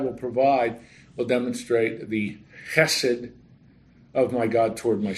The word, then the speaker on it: chesed: The Hebrew word is chesed. The Hebrew word is chesed. H-E-S-E-D. Sometimes you'll see it written C-H-S-E-D, trying to put in writing the guttural sound to chesed. That's really how you pronounce it.